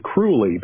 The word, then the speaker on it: Crowley: And I've got a British friend, by the way, who says he probably pronounced it cruelly because he liked, you know, people to think that he was cruel. He called himself the Great 666. Whatever. I've heard Crowley rhymes with holy, but whatever.